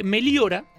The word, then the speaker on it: Meliora: Meliora, en latín, el caballero Fuente Rodríguez, aquí a mi derecha, sabrá corregirme si me equivoco, si lo digo mal. – No, está bien.